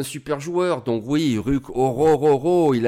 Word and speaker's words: Orhorhoro: ça se prononce assez facilement. En tout cas, c'est comme ça que le disent les Américains. Est-ce qu'ils le disent bien Je sais pas, mais Orororo, on va le dire comme ça.